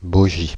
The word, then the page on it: bogie: France (Île-de-France)